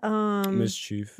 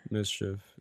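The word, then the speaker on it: MSCHF: Mischief. – Mischief, right. – Mischief, yeah. – Yeah, something like that. – Mischief. It's spelled weirdly. – Yeah, without the vowels, I think.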